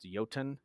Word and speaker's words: Jotun: So they made that game called, I think it's pronounced Jotun, but it looks like Jotun.